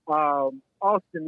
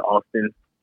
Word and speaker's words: Alston: Alston, yeah, I mean, it does have an L in it, but we just pronounce it Alston, you know? – Okay.